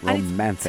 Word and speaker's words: Romanthony: And it's sung by this guy called Romanthony. Hilarious name. – Romanthony. – Yeah, because it's so odd. – It's but like Romance and Anthony put together. Romanthony.